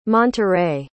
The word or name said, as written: monterrey